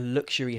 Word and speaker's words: luxury: And I want to point out the difference between the pronunciation of luxury. – Mm-hmm. – It's a luxury hotel and luxurious. A luxurious hotel. The stress is different there.